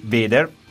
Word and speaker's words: Vader: Peraltro loro, stiamo parlando dei Vader. Dimmi se pronuncio correttamente. – Sì, sì, correttamente.